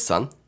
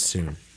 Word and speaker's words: Sun: Was that Miss Sun? – Yeah, Miss Soon. – Miss Soon? – It was spelled Sun, but yeah. She always pronounced it Soon.